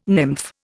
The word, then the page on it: nymph: Indian